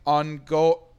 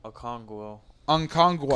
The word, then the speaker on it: Okongwu: Onyeka Ongo. – Okongwu.